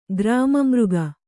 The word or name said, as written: ಗ್ರಾಮಮೃಗ